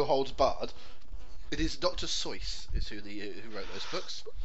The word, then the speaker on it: Seuss: But it's Dr. Seuss. It's not Dr. Zeus.